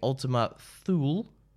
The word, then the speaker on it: Ultima Thule: At 11 p.m., you'll be having Ultima Thule. Rhymes with creme brulee.